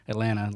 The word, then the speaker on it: Atlanta: I've been to every food hall I could find in the Southeast, and there's one in Atlanta on did you guys grow up in the South, too? – Yeah. – So I say everything Southern. Atlanta, like there's no T at the end.